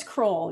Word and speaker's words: Croll: Jennifer, how do you say your last name? Is it Croll? Croll. – It's Croll. Yeah.